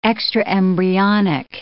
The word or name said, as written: extraembryonic